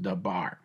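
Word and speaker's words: dabar (דבר): That word matter is the Hebrew word dabar, spelled D-E-V-A-R, but pronounced dabar.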